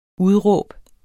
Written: udråb

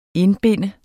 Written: indbinde

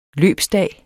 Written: løbsdag

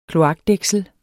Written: kloakdæksel

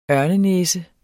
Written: ørnenæse

